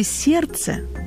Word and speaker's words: сердце: Например, в слове «сердце» буква «Д» не произносится, но она связывает его со словом «сердечный», где звук отчетливо слышен.